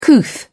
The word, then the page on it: couth: British English